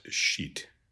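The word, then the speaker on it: scit: Lots of schoolboy jokes about this word, because as you can imagine, the third person singular in ecclesiastical Latin would be pronounced sheet.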